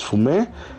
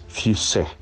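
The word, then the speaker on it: Fuissé: Ojo, aquí no hay que confundirlo con el Puy Fumé que se elabora en, el Val de la Loire, en, la parte norte de Francia. Son muy parecidas la, pronunciación, pero allá es Fumé y aquí es Fusé.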